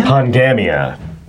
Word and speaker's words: Pongamia: So again, the pronunciation, Pongamia? – Pongamia.